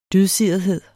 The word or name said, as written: DYDSIRETHED